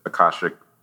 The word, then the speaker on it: Akashic: If when people get a, a business Akashic record reading, I'm not sure if I'm saying that correctly.